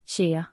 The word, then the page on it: Zheer: /ʒɪər/; Like ‘dear’ but starting with the ⟨zh⟩ sound found in, for instance, ‘measure’; American English